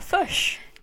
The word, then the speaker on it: fish: Which is also how you pronounce it if you're in New Zealand. – Is it? – Yeah, fush.